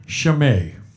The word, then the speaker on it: Shimei: But I remember in Hebrew, A-I is pronounced I, E-I is pronounced A. That's maybe a different part of Israel, and maybe it's different, I don't know. But the story is about David and a man named Shimei.